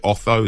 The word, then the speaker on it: Otho: Like you say, is it Otho?